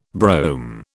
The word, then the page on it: brome: British English